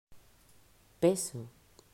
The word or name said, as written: Peso